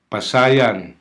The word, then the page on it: pasayan: Hiligaynon